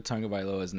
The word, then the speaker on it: Tagovailoa: We've got learned how to actually say Tua Tagovailoa's name. It's Tagovailoa, but it's spelled like a T-A-U.